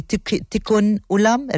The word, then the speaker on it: tikkun olam: please tell me if I say it correctly, tikkun olam, repairing the world, which sees the olam, you said it perfectly.